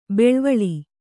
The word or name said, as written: ಬಳ್ವಳ